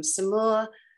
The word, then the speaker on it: Samoa: So I loved that, and, you know, the doctors there were Sri Lankan doctor, one from Burma, one from Samoa, actually. That's how they pronounce it.